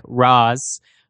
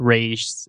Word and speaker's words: Ra's al Ghul: In Arabic, you pronounced Ra, apostrophe S, like Raz. So Raz equals head, Al, or of the, or simply the, and Ghoul, demon Ghoul, so he's the demon's head, or the head of the demon. Danny O'Neill, who is the person who wrote Batman Beyond and includes a clip where Talia al Ghul pronounces or corrects the Batman Beyond kid as saying that it's not Raish, it's Raz, it's Raish.